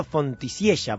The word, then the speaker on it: Fontisiella: Marcelo Fontisiella, porque así se pronuncia, ¿eh? – Sí, así es. – Dicho por él mismo. – Porque no es de origen italiano el apellido, sino español.